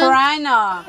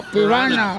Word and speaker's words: piranha: Oh, ¿en inglés cómo se dice piraña? – Spider-Man. – Piranha. – Oh, piranha. Piranha. – Habla bien piranha.